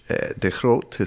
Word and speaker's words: de Groot: Hence the name, de Groot, which de Groot is how they pronounce it in the Netherlands, and it means the great.